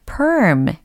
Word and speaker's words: perm: 파마가 아니고 perm. P-E-R-M. perm, perm, perm. 좋아요. 뭔가 머리카락이 꼬불꼬불해지는 느낌이 들지 않나요? 발음 자체가 perm. 중간에 R 사운드가 들리니까 꼬이잖아요. perm.